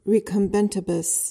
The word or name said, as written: recumbentibus